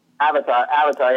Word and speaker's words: Avatar: Avatar, yeah.